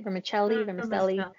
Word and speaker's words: vermicelli: So it was pretty much a vermicelli. Is that how you say it? Vermicelli.